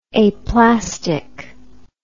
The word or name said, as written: aplastic